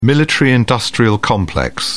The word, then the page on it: military-industrial complex: British English